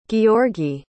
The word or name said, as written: georgii